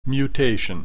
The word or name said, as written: mutation